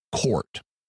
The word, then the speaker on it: CORT: These were principally focused on case studies of what Stevenson called cases of the reincarnation type, or C-O-R-T, which is pronounced court.